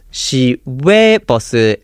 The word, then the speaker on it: 시외버스: So if you listen here, the difference is Shiwe bus and Shine bus. So the second syllable is switching back and forth from we to ne. So the word we is referring to outside, and the word de is referring to inside, and the very first syllable she is referring to the city of Seoul or the city that this bus is in. So 시내버스 is inside the city limit.